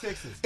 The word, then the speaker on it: Techsys: Texas. It's spelled TECHSYS. But I think it's intentional that they call it Texas.